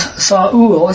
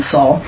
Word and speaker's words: Saul: And he had a son whose name was Saul. It's really two syllables, not one Saul. Saul,